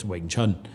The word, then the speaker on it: Wing Chun: And I basically talked about how, you know, all of the, it's always pronounced the same. It's Wing Chun.